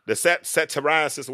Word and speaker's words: satyriasis: But yeah, but the thing is the satiriasis, whatever.